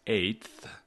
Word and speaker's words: eighth: The answer is eighth. The G and H are silent, and the T, the fifth letter T, is pronounced first as a T and then as part of TH.